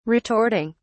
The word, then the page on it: retorting: British English